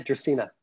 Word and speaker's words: Dracaena: I have Dracaena. Is that how you pronounce it? – Yeah, Dracaena.